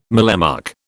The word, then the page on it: mallemuck: British English